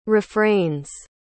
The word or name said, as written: refrains